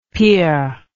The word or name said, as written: pire